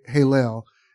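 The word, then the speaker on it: Hillel (הילל): is the rendering of the Hebrew word transliteration, Hillel, pronunciation, Hillel, in Isaiah 14:12, given in the King James Version of the Bible.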